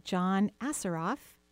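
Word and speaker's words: John Assaraf: And next week we'll be interviewing John Assaroff.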